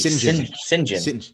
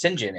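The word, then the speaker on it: St John: that apparently the way you pronounce this name in England would be Sinjin. – Yes, I did know that. – It's Sinjin.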